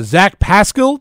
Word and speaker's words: Pascal: If you do want to text in the show, Zach Pascal.